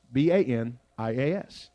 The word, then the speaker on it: Banias: It's called Banias. B A N I A S. Banias. It's called that because the local people had a hard time pronouncing the name the Greeks gave to it when they built it. Panias. Panias. I guess they couldn't pronounce the B's and, and P's.